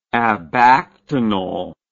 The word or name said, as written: abactinally